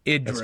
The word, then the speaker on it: Idris: It's Idris. – That's chat room talk for you, Chris. – Idris Elba. – Idris Elba. – With that's a short I.